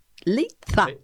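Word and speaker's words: Leitza: Leicia. Leiza. – Leiza. – Leiza. A ver si lo digo bien. Lo dije.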